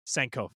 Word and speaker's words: Sankofa: Or Sankofa, I'm not pronouncing it properly, I'm sure. – Oh, Sankofa. Sankofa, thank you.